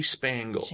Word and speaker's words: Spangle: Jamie Spangle. – Wow. – Yeah. Rhymes with dangle.